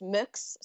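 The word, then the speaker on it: Mx: It's usually pronounced mux, so it's basically just M and then an X.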